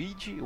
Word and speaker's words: id: Eu acho que eu mencionei isso lá no episódio 21 do Wolfenstein que é, o nome correto seria id, né? E não id, como muita gente é, pronuncia, Exato.